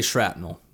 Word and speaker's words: shrapnel: I say shrapnel because I know it's spelled with a P, but I do hear people say it with a T. – Let me just see. Here we go. – Shrapnel. – Shrapnel. Yeah, no T.